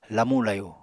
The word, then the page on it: lamu-layu: apatani